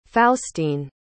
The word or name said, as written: faustine